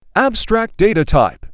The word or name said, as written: abstract data type